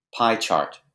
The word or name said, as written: pie chart